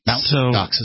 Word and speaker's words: Mt. Gox: Mt. Gox is how they say it.